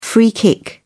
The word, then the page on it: free kick: British English